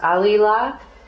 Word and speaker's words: alila: And there's the word for wailing in Aramaic, it's pronounced alila. And the way they would wail, it would be like, alila, alila, alila, alila, alila, alila, alila.